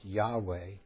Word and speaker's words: Yahweh: seems to have been the first to suggest the pronunciation Iju, pronounced Yahweh, but it was not until the 19th century that it became generally accepted.